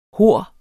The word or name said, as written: hor